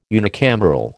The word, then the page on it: unicameral: American English